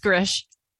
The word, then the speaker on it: Grzych: So now it is my wonderful pleasure to introduce to all of you author and Ayurvedic practitioner, Heather, I'm going to butcher your last name. Grzich? – You could say it's Grish.